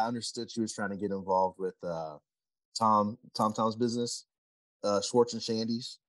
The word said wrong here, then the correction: Sandy's: Yeah, Schwartz and Sandy's.